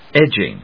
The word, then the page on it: edging: edg･ing